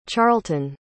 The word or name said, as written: charlton